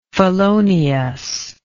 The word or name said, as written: feloniousness